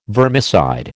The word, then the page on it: vermicide: American English